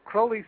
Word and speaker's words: Crowley: But Crowley said his name rhymed with holy.